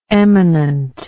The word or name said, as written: emanant